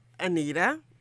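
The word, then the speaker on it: Annita: And of course, I'm Anita Thomas, that Anita with two N's in her name, spelled a little bit differently, but still pronounced Anita, or rather my mom used to call me Anita.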